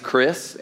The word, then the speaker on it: Kris: My name is Chris, and my name is spelled K-R-I-S, and for those of you who are older than 45, I'm named after Chris Christopherson.